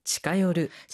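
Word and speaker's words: 近寄る: 치카요르. – 자, 이렇게 요르라고 읽어서 치카요르가 되는데.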